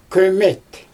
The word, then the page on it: köömet: Xyzyl